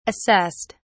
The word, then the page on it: assessed: British English